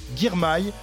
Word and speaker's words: Girmay: Il nous vient d'Érythrée, se nomme William Girmay. C'est comme ça qu'il faut euh, prononcer.